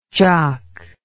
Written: jauk

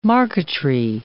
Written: marqueterie

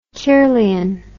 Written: kirlian

